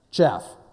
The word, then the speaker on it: chaff: The whole statue turns into chafe or chaff. Never know, how do you say that anyways? Chaff, yeah. Chafing is something, yeah, no, that's different. We're not going to talk about chafing this morning, we talk about chaff.